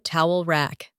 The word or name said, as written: towel rack